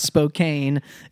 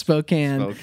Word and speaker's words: Spokane: It's like, I'm from Spokane and it's not pronounced Spokane. It's pronounced Spokane.